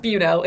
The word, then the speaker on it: Buesnel: It's pronounced Buesnel, in case anyone wants to know for future reference.